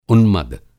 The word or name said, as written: उन्मद